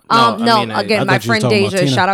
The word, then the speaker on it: Aminé: I don't think it's anime. – Aminé.